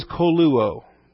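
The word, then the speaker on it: koluo (κωλύω): The verb is koluo. K-O-L-U-O. They're both omegas there, long O's. Kappa, omega, lambda, upsilon, omega.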